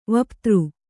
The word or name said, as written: ವಿಪತ್ತಿ